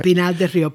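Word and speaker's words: Pinar del Río: Originally, from Pinar del Rio, did I pronounce that right? – Pinar del Rio, Pine of the River.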